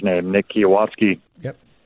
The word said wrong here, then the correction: Kwiatkoski: Kwiatkoski, he's going to make a lot of money.